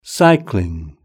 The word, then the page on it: cycling: British English